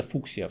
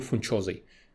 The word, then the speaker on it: фуксия: О, мне пишут вон, что правильно читается фуксия. Все отлично. Я на самом деле в следующий раз опять забуду и опять назову фунчозой.